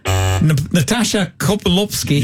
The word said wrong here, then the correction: Natasha Kaplinsky: Natasha Kaplinsky.